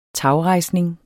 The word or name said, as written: tagrejsning